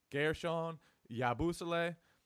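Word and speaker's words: Guerschon Yabusele: Gershon Yabusele, however you say his name, I still can't get that. – You nailed it that time. You got it.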